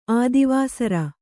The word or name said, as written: ಆದಿವಾಸರ